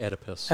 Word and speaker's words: Oedipus: Oedipus. – Oedipus, fall, rise, fall.